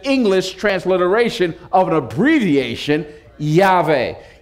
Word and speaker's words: Yahweh: In the Hebrew, phonetically, the abbreviation is something like Yahweh, Yahweh.